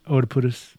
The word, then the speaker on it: Oedipus: You just used the Oedipus word. – Oedip- Oedip- yeah, Oedipus. – How do you say it again? – Oedipus.